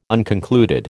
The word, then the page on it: unconcluded: American English